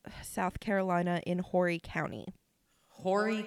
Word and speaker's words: Horry: I I heard a couple of people pronounce it like Ori. Uh, yeah. But other people pronounced it with an a like Hori. So I'm.